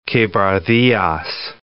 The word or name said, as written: Quebradillas